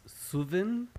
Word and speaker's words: Suvin: Okay, it's Suvin. To break it down grammatically, if you take the word put and change it to soot, so S-O-O-T, you want that U sound, and then take away the T and add vin, so Suvin.